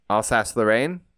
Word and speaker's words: Alsace-Lorraine: Alsace-Lorraine. Did I say that wrong? – Yeah, no, you did. Say it beautifully.